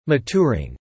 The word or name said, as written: maturing